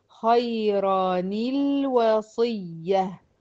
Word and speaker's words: خيرا الوصية: فهتتنطق ازاي؟ زي اللي تحت المكتوبة دي عندك في الملزمة، خيراني الوصية، خيراني الوصية.